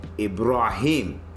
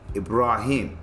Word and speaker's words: Ibrahim: Now, I might get, now my name is Ibrahim. That's how you say it in Arabic. Ibrahim. That's how you pronounce it. Ibrahim. Swahili, Ibrahim. So it's Ibrahim or Ibrahim. That's how you pronounce my name.